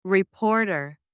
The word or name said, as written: reporter